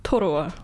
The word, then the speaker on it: Toroa: What's the correct pronunciation for Toroa? – Toroa. – I think it's Toroa. – Toroa. – Yeah. And then Toroa is like the Malaysian version.